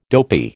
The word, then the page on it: dopey: American English